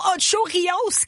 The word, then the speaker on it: Ocho Rios: Rio Ocho Rios, Kim. – Oh mon Dieu, tu le prononces bien depuis une semaine, c'était pratiqué.